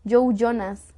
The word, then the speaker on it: Joe Jonas: Este, no sé si alguna vez escucharon de la relación de Joey no, Joe, ¿cómo se pronuncia? Joe Jonas, sí, ese, este bro.